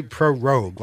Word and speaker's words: prorogue: Well, I heard them say prorogue, like rogue. – Prorogue. – And it's got the word pro in front of it. So if you can say prorogue.